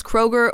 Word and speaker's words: Kroger: So for example, Kroger, well, I think was like, was it Kruger? – I think it was Kruger. – Probably, yeah. So, like, basically, they just dropped the umlaut, and now it's pronounced Kroger.